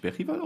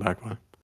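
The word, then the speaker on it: Bergvall: Also dann lasst die Leute halt ihre, dann lasst vor allem Gray und wird der wirklich Berrival ausgesprochen? – Ja, ich habe es mir angewöhnt. Es fühlt sich ein bisschen falsch an, aber die schwedische Aussprache ist tatsächlich Berrival, ja. – Ach, der Tottenham-Kommentator, also der Vereinsinterne, der sagt das tatsächlich auch. Alle anderen sagen Bergwall. Ja, du darfst auch Bergwall sagen, wenn du möchtest.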